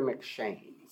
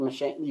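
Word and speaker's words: M'Cheyne: It's Robert Murray McShane. So you probably know him from his Bible reading plan. The McShane, you can't pronounce it every January, but it's MC apostrophe C-H-E-Y-N-E.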